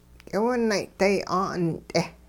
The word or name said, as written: k'iwinay'day 'aa'unt'e